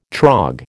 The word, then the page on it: trog: American English